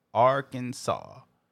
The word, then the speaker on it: Arkansas: Arkansas is pronounced one way and only one way. Three syllables with the accent on the first and the last syllable. Arkansas.